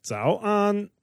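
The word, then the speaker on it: Zao an (早安): Zao an.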